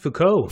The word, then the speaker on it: Foucault: You had deconstructionism, structuralism, people like Michel Foucault, Jacques Derrida. – Is his name pronounced Foucault? – Foucault, yeah.